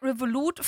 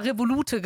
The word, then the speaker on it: Revolut: Luca, weißt du, ich habe die ganze Zeit Revolut falsch ausgesprochen. Weil Alex meinte noch so, wenn Luca dich fragt, wie man das ausspricht, ich habe mal Revolute gesagt.